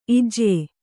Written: ಇಜ್ಯೆ

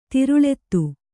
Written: ತರಳಿತ

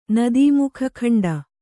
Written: ನದೀಮುಖಖಂಡ

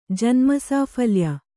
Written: ಜನ್ಮಸಾಫಲ್ಯ

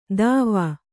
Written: ದಾವಾ

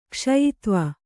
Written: ಕ್ಷಯಿತ್ವ

